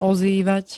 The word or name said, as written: ozývať